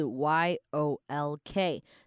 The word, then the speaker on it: yolk: 然后 yolk 这个字呢，你会看到它中间是 y o l k，但是那个 l 我们不会特别要把它发音发出来，所以其实就是 yolk。